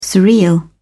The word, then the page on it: surreal: British English